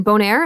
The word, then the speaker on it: Bonaire: So you were there with your crew, Bettina and Carenza, from your island, Bonaire. Am I saying that right? – Yes, you're totally getting that right.